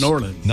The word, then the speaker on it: New Orleans: The locals say Norlands. – Norlands.